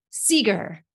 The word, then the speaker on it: Segar: Michelle, how do you say your last name? – Seger. – Seger, SEGAR.